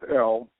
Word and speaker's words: El: We pronounce it El, and it just means God.